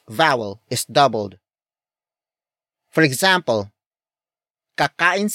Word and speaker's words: kakain: Kakain sila sa labas.